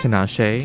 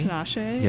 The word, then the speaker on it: Tinashe: Tinashe. – Tinashe.